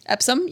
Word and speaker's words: Epsom: Epsom? – Epsom, yep.